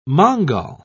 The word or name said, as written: mongol